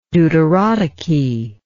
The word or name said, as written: deuterotoky